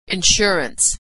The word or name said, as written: insurance